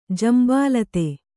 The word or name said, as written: ಜಂಬಾಲತೆ